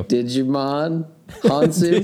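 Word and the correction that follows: Djimon Hounsou: Do you know how to say his name? – Jamin Hansu. – Jamin. Hansu. Oh, that's way easier, yeah.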